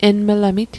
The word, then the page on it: enmlamit: Mi'gmaq